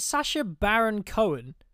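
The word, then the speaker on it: Sacha Baron Cohen: Is it Sasha Baron Cohen? – Yeah, yeah.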